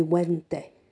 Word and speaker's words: venti: So it's V-E-N-T-I, but the old style Latin, you would not pronounce the V. It would be vente.